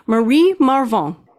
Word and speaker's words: Marie Marvingt: Marie Marvingt.